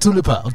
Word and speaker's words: Tulipa: Tell me all about Tulipa.